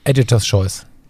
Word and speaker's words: Editor's Choice: Editor's Choice.